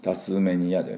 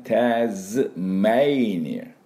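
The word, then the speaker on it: Tasmania: これタスマニアじゃなくてメイニア、タズメイニア、エイの音ですね。だからタズメイニア、タズメイニアっていうのが正しい、ネイティブの音になります。タスメニアでタズ、ね、タズメイニア、メイにアクセントがあります。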